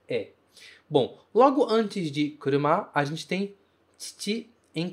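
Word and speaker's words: kuruma (車): E esse kanji, se pronuncia kuruma, significa carro. Então, no final da frase, a gente tem kuruma desu, kuruma desu.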